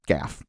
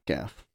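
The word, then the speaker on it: Gough: Let's not forget about Michael Goff. Gaff. I keep saying it wrong. Michael Gaff. – Gaff.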